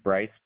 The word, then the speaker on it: Bryce: My first name is Bryce, B-R-Y-C-E. – And B-R-Y-C-E?